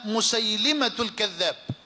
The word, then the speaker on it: مسيلمة: هذا سند الإمام البخاري، وإلا فالحديث مخرج أيضا في صحيح مسلم رحمه الله تعالى، عن ابن عباس قال: قدم مسيلمة الكذاب، والتحقيق أن تنطق لفظة مسيلمة بكسر اللام لا بفتحها،